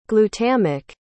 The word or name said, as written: glutamic